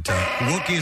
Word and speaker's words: Wookey: It's a real place in England, but Wookiee is spelled W-O-O-K-E-Y. – Not like Chewbacca.